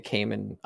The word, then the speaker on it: Cayman: It's spelled like gay man, but with a C instead of the G. Cayman.